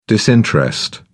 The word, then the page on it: disinterest: British English